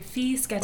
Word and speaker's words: Fischetti: Fischetti. – Bischetti? – Fischetti. – Oh, Fischetti. – Say it right.